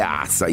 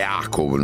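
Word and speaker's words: Aca: La prima grande squadra di Petrovic è il Cibona Zagabria, dove gioca il fratello, si direbbe Azza, io direi Aco, noi lo pronunceremo così,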